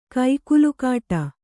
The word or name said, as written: ಕೋಗಿಲೆಗಿಡ